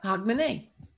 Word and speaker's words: Hogmanay: Hogmanay. – Just like it looks. – Hogmanay? – Yep.